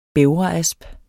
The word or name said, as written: bævreasp